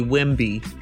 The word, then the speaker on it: Wemby: Victor Wembley. – Just call him Wemby, not Wembley. Wemby. That's it.